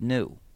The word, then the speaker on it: GNU: And not only that, the word GNU is used in lots of humor, because according to the dictionary, it's supposed to be pronounced new. So every time you want to write the word new, instead of spelling it N-E-W, you can spell it G-N-U, and you've got a joke.